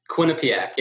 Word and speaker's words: Quinnipiac: Now, I think, is it pronounced Quinnipiac University? – Quinnipiac, yeah. Quinnipiac. That was really close.